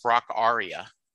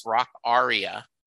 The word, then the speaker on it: Rockaria: And he said, do you know it's pronounced Rock Aria? And I said, get the F out of here. He said, yes. He said, all my life, I've called it Rockaria as well.